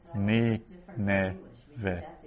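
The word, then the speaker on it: Ni, ne, ve (נִינְוֵה): The first syllable is ni. Ni, it's an open syllable. And then the second syllable is n. So nun with a shva. And then the final syllable is ve. Ve. Ni, ne, ve.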